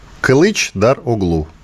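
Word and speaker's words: Kılıçdaroğlu: Кылыч Дар Оглу.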